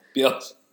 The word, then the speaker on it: Bielsa: Bielsa. – Yeah. – The L's before the S.